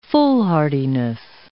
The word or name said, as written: foolhardiness